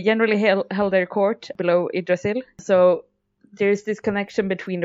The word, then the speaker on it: Yggdrasil: And I'm going to be annoying and pronounce it in Swedish, all the Norse names now. But anyway, Yggdrasil is from Norse mythology and is the world tree.